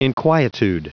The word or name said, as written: inquietude